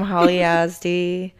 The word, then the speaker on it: Holly Yazdi: Oh yeah, follow me on Instagram, Holly Yazdi.